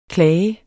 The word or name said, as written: klage